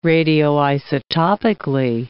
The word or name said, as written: radioisotopically